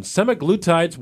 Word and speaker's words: semaglutides: Semaglutides, what are they and how are they contributing to weight loss in children? – Well, you nailed it. That's how you pronounce it.